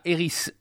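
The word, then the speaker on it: Erik: Alors, d'abord, il y a Eris, c'est comme ça qu'on prononce là-bas chez eux, hein.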